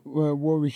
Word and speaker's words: Warwick: Pronounce Warwick.